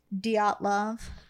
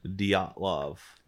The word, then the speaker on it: Dyatlov: Well, there's did how do you pronounce it? – Dyatlov. – Dyatlov. – Dyatlov Pass incident.